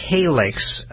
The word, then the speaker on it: Calyxt: The company's name, again, is Calix. That's spelled C-A-L-Y-X-T.